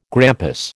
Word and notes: grampus: American English

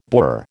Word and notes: borer: American English

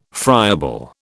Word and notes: friable: British English

